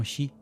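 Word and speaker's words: Shi (四): Y claro, es que el número 4 se puede pronunciar como Shi, y la palabra Shi significa muerte. Entonces,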